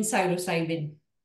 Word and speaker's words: psilocybin: So is the How do you say it? Psilocybin? – Psilocybin, Yeah.